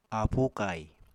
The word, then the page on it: aapu kai: Apatani